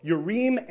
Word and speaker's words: Urim: or sometimes people pronounce it Urim and Thummim. Okay, but in Hebrew, the I-M is plural, and it's pronounced im. So Urim and Thummim, two stones, and they would actually light up if you...